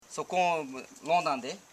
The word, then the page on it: sokonlondande: so.kon.lon.dan.de